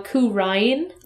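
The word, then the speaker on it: Khura'in: They are forcing now Kurain, which is more accurate for how the Japanese pronunciation is. But they put an apostrophe in it now, so it's K-H-U-R apostrophe U-R-A apostrophe I-N.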